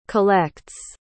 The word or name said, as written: collects